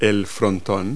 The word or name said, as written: pediment